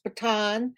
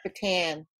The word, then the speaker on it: Bataan: If you're from the Philippines or we're a POW, it's Bataan. If you're from the New York metropolitan area, it's Bataan.